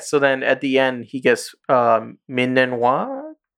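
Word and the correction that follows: Ming-Na Wen: Yeah, Ming Na Wen. I, I fucked that all up. I said Ming Then. Anyways, Ming Na Wen.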